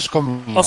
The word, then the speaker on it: Skomina: Credo si pronunci Skomina. – Skomina, buonasera avvocato innanzitutto, benvenuto in trasmissione.